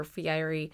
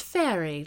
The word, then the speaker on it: Fieri: But when I hear someone say, no, it's pronounced Fairy, like the boat, I'm like, really? Is that true? – No, I don't think so. – I feel like I've heard him say Fieri.